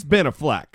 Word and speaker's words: Affleck: No, everyone should say it that way. I'm convinced it's the right way to say it. – Okay. – It's Ben Affleck.